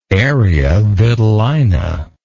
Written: area vitellina

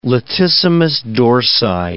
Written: latissimus dorsi

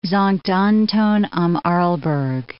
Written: Sankt Anton am Arlberg

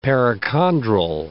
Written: perichondral

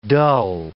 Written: dull

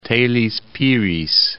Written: Teles Pires

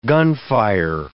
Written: gunfire

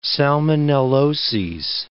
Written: salmonelloses

